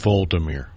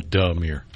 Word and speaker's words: Volodymyr: I think it's Volodymyr. – You're right, V-O-L-O. So is the O, that second O, kind of Volodymyr. Okay. Almost like Voldemort.